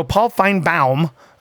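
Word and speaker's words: Paul Finebaum: So Paul Feinbaum, apparently, is how it's technically pronounced.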